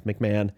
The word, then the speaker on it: McMahon: I know how to say it. It's McMahon.